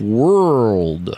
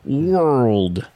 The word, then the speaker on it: world: World. – World. – Yes, you have to really use the muscles in your mouth. World.